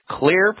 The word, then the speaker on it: Xlear: Clear is spelled X-L-E-A-R. That X stands for xylitol.